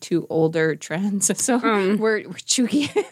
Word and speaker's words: Cheugy: That's how you pronounce it. Yeah, but it's spelled Cheugy. So I would have said chuggy.